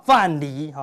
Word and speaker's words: 范蠡: 哈，木子李的李，好不好？范蠡，哈，他是一个商以前的是什么？